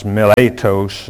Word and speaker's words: Miletus: And from Miletus, in the Greek this is actually pronounced Miletos, and less correctly called Miletum in 2 Timothy chapter 4, verse 20, according to the Smiths.